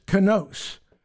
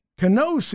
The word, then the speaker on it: kainos: However, the word that's used for the new wineskins is kainos. We would spell it K-A-I-N-O-S.